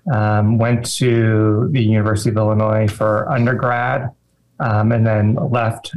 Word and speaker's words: Illinois: And first of all, I got to ask, do you pronounce it Illinois or Illinois? This is a dumb Southerner question. – Illinois.